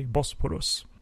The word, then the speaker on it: Bosporus: But regardless, new scenes were filmed with Carter Wong, Kong Do, and Bolo Young that was inserted into Karate on the Bosporus from 1974. Is that how you pronounce that area of Turkey? Bosporus. – Yes, I believe that Bosporus is how it's pronounced, but I'm not really an expert, I'm afraid.